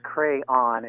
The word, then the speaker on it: crayon: On page 70, it says clearly that it's SAB, S-A-B. And that it's crayon in two syllables, not one, or triphthong, crown.